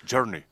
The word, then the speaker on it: Journey: Journey.